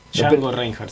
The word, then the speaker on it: Django Reinhardt: Está mal dicho diangología, ¿no? – Sí. – Mira vos. Y es, sí. O sea, es Django DJ. – Reinhardt. – Django Reinhardt, sí. – ¿Se pronuncia así? – Sí.